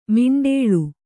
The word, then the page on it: ಮಿಂಡೇಳು: miṇḍēḷu; Kannada